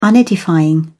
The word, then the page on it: unedifying: British English